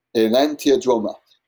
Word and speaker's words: enantiodromia: Someone pronounces it the same as me, and enantiodromia, and someone else says, nah, that's pronounced wrong, because he's introduced on the stage to give a talk, and he says, yeah, you did pronounce it wrong. It's enantiodroma.